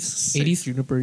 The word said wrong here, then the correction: San Junipero: you guys are saying that wrong. – Is it? – How do you say it? – San Junipero.